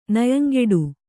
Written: ನಯಂಗೆಡು

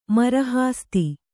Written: ಮರಹಾಸ್ತಿ

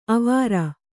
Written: ಆವರು

